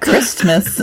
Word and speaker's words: Christmas: Do you pronounce the T in Christmas? – I was about to ask, why are you doing that? – Christmas. – I don't like that. – I mean, you can, I guess.